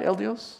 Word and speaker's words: El Dios: Am I saying that right? El Dios? Is that right? Yeah, close enough.